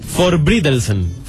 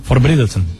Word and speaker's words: Forbrydelsen: Sí, Forbrydelsen. – Forbrydelsen.